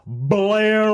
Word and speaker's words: Bel Air: B-E-L-A-I-R. That's how you spell the name of the road. Would always pronounce it Blair Road. And it really pissed me off. – Yeah, but he was... – I'm right here on Blair Road! – Yeah, but, like, the problem is, if he said Bel Air, white people wouldn't have known how to find it. – Yeah, because that's how white people pronounced Bel Air Road. Blair Road.